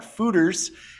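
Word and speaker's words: Fuder: But there's been a little bit of talk about fooders, and those are very large. Oak Fodor? – The word looks like fodor, but it is pronounced fooder.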